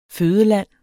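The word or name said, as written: FØDELAND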